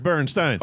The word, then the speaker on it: Bernstein: There's only one way to say his name. Leonard Bernstein.